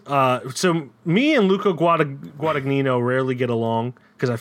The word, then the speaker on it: Guadagnino: Uh, Guadagnino, yeah, Luca Guadagnino.